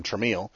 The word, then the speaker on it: Tramiel: Instead of T-R-A-M-I-E-L, because everyone was calling him Tremel, he actually made the company's name Tremel just to make sure that people actually pronounced it right.